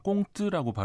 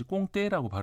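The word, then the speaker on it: comte: 정작 프랑스에서는 꽁트라고 발음하지 꽁떼라고 발음하지는 않아요.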